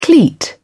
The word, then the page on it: cleat: British English